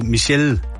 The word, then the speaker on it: Michelle: Ja, så det er jo et fransk navn, der ender på L. Michelle. – Det ender jo på E. – Præcis. – Jamen, det udtales jo Michelle.